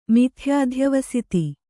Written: ಮಿಥ್ಯಾಧ್ಯವಸಿತಿ

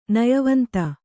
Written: ನಯವಂತ

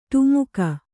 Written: ಡಿಮಕಿ

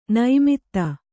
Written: ನೈಮಿತ್ತ

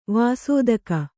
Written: ವಸ್ತುಕ

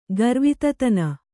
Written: ಗರ್ವಿತತನ

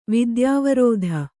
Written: ವಿದ್ಯಾವಾರಿಧಿ

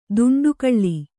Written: ದಂಡೆಗೊಳ್ಳು

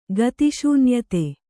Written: ಗತಿಶೂನ್ಯತೆ

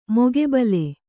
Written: ಮುಖಾಬಿಲೆ

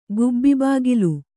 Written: ಗುಬ್ಬಿಬಾಗಿಲು

